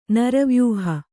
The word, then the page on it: ನರವ್ಯೂಹ: naravyūha